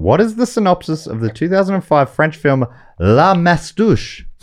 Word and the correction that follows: La Moustache: La Moustache.